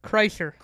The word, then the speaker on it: Kreischer: Kreischer.